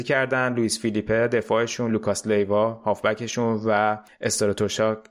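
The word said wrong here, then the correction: Strakosha: است... استراشوکا من همیشه اسم بنده خدا رو اشتباه تلفظ میکنم استراکوشا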